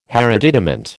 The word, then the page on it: hereditament: American English